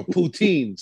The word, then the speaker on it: poutine: You want this Putin? No, poutine. Sorry. Said it wrong.